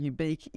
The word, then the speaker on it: Ubique: Ubique which for people listening, ubique is spelt like unique, but with a B instead of an N.